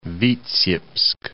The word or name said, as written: Vitsyebsk